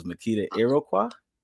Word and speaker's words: Makeda Iroquois: I know her first name because my sister's name Makeda as well, but her last name is Makeda Iroquois. Is that it? – It is. You said it right the first time. The S is silent and everything else is pronounced how it looks.